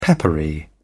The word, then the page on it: peppery: British English